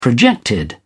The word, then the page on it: projected: British English